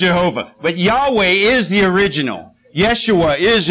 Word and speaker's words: Yahweh: Most accurately, it would be pronounced Yahweh.